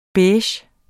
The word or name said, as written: beige